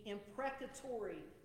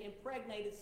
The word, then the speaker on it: imprecatory: Each of these statements would fit well into the genre of the Psalms that scholars call the imprecatory Psalms. And I'm going to spell that for you because you may, it sounds like I said impregnated Psalms. And no, that's not right. It's imprecatory. I-M-P-R-E-C-A-T-O-R-Y.